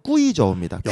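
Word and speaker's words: 꾸이저우입니다 (贵州): 중국어 발음은 꾸이저우입니다.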